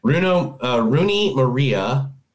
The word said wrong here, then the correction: Rooney Mara: Am I saying that right? Rooney Maria? – Rooney Mara.